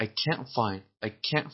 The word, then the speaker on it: can't: توی یک جمله وقتی تلفظ میشه اگر طرف بگه I can't find، این معلومه کنته، چون استرس و فشار و تشدید روی کلمه کنته. اگر بگیم I can find، استرس روی کلمه find باشه و can رو آهسته تر و با استرس کمتر تلفظ کنیم، اون موقع مثبته کنه. اگه با استرس بیشتر تلفظ بکنیم اون موقع منفیه، کنته.